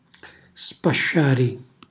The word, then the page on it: spasciari: Calabrese